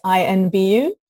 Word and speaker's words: INVU: Invu or I-N-V-U? – I think they want you, to, like it's more pronounced as I-N-B-U.